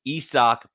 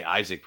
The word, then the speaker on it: Isaac: This is Isak Paredes. I want to say Isaac Paredes, but it's Isak is how the pronunciation is on Baseball Reference.